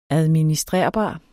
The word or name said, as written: administrerbar